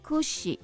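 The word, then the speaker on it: くし: 比如说在日本的话，有些人不喜欢收到梳子，不要送人家梳子，闲爪哈，这个梳子那个的发音哈，kushi 好。那个苦是苦哈，跟苦是同样的音，是跟死是同样的音，所以会让人联想到苦跟死哈。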